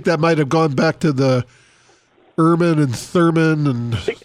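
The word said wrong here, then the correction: Thummim: I think that was pronounced Thumman. I said Thurman. – Yeah. – I pronounced that wrong.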